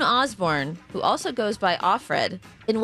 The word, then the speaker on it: Offred: They pronounce it Offred.